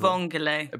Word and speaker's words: vongole: Al bongole. Al bongole. But I probably said, I probably tried to really, really jazz up the pronunciation of it there. – Mm-hmm. Vongole.